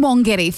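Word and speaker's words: Wangetti: It's always, it has been Wongetti. For a long time, I'm also a local like Lisa. And we called it Wongetti even though it's spelled W-A-N-G.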